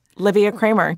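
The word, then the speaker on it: Liviya Kraemer: So if the people want to find me, it's, Livia Kramer, just my name, which is spelled very strangely, LIVIYAKRAEMER.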